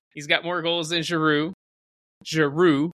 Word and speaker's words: Giroud: Did you just say Giroud? – What? – Did you say Giroud? – Giroud. Olivier Giroud. – Giroud. French don't pronounce the last letter.